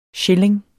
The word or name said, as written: schilling